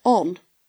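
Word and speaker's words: Ohne: So Orna is a German word meaning without, and this article said it's pronounced Orna. – Mm-hmm. – But then in an interview with The Woman, Leah Renfrey Peplow says, we pronounce it on.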